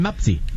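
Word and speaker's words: Mpasi: No va a sonar muy conocido porque la pronunciación es Lionel Mapsi.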